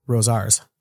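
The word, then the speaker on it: Rosauers: I was at Rosar's. That's how it's pronounced.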